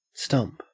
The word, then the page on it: stump: Australian English